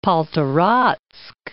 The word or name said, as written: Poltoratsk